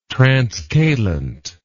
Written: transcalent

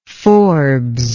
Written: Forbes

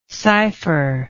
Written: sypher

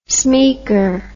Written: smeaker

